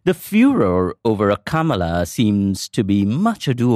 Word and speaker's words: Kamala: Joe Biden's pick of Kamala Harris as his running mate reminded me of that brief encounter.